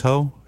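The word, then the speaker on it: Heaux: She said, Dad, it's H-E-A-U-X, but it's pronounced ho, just so you know.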